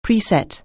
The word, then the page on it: preset: anglais